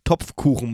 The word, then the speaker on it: Topfkuchen: Also da konntest du alle Variationen, Formen, Farben von Torten und Kuchen, äh, Blechtuchen, Kopf, Kopftuchen heißt das vor eigentlich, ne? Kopftuchen. Topfkuchen, Mann. Topfkuchen.